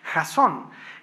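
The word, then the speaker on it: jazón (חזון): En hebreo se pronuncia jazón.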